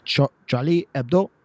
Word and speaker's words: Charlie Hebdo: like Charlie Hebdo. – No, that's the right way to say it. Charlie Hebdo.